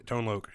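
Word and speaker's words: Tone Loc: Yeah, Tone Loke.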